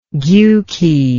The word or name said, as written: gjuki